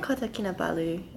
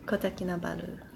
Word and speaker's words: Kota Kinabalu: Like, well, how we pronounce it here is Kota Kinabalu. – Kota Kinabalu. Yeah. Okay, great. Yeah, not like Kota Kinabalu. – Oh, no. – No.